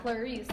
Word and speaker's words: Clarice: Clarice, that's how you pronounce her name. Great.